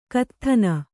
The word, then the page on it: ಕತ್ಥನ: Kannada